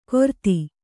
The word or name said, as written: ಗೊರತಿ